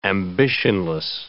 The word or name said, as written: ambitionless